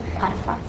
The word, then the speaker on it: Potiphar: Potiphar. – Yeah. – Potiphar? – Uh-huh.